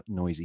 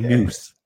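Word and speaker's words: Neuse: I like him even more that I know that he's pronounced Noisy. He was on the Dodgers forever, and I must have watched the games on mute because I didn't know that that's how you pronounce his name. I was like, Noose.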